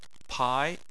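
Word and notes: pai2: tone 2

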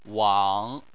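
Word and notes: wang: tone 3

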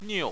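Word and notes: niu: tone 4

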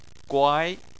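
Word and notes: guai2: tone 2

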